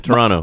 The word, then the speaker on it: Toronto: There's no T on the end. Toronto. – That's correct.